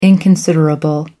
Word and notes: inconsiderable: American English

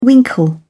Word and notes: winkle: British English

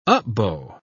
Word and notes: up-bow: British English

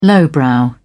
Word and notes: lowbrow: British English

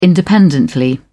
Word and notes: independently: British English

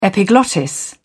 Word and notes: epiglottis: British English

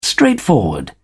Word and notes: straightforward: British English

